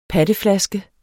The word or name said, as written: patteflaske